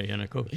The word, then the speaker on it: Yanukovych: but Viktor Yanukovych. – Yeah, Yanukovych.